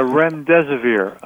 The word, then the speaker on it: remdesivir: Now, there is a drug that they're talking about, and you brought it up also, remdesivir. Am I pronouncing it correctly? – Yeah, remdesivir.